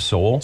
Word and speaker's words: Soule: Is it Soul? – Soul, yes. – S-O-U-L-E, Richard Soul.